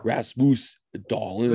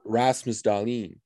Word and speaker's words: Rasmus Dahlin: It's not Rasmus Dahlin. Whatever. It's Rasmus Dahlin.